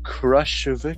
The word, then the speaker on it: Khrushchev: It's like Khrushchev.